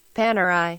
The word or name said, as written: Panerai